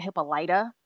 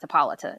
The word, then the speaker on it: Hippolyta: Um, they keep pronouncing it Hippolyta, but I've always heard it pronounced Hippolyta.